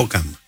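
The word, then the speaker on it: Hokum: Είναι το Χόκουμ ή Χοκούμ ή κάτι τέτοιο. – Χόκαμ. Ναι. – Χόκαμ, συγγνώμη.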